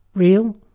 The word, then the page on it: real: British English